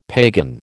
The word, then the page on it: pagan: American English